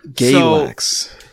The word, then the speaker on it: Galax: Galax. – Galax, yeah, like Galaxy without the Y. It's pronounced Galax, yeah.